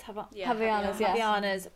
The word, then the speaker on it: Havaianas: Havaianas.